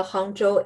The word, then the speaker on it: Hangzhou: Did I say that right? Hangzhou? – Yeah, it's a Hangzhou Airport.